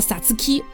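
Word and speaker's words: 皐月: 嗯，这个高字很难写，它在日语里面读作 Satsuki，这个意思其实指的是日语当中的五月。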